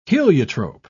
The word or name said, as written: heliotrope